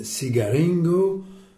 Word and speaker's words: cigaringo: Sed cigaringo parte antena cigaron. – Voilà. Tse, hein, pour la lettre C se prononce C. Donc, cigaringo.